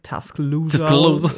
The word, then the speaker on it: Tuscaloosa: Ich hoffe, ich habe es richtig ausgesprochen. – Ja, sicherlich ein bisschen wenig Slang. Tuscaloosa. Irgendwie sowas. Ja. Aber ja, das hätte ich auch so ähnlich gesagt.